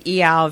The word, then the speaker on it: Iao: And went to the Iao Valley on the north side, which it is pronounced like meow, Iao.